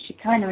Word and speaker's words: Shekinah: It's funny, I was in the shower the other day and I just kept repeating Shekinah, and I can never remember how you pronounce it, and you pronounce it Shekinah, and it's almost like I was being given a name to remember it.